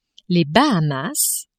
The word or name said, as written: Bahamas